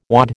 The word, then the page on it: wad: American English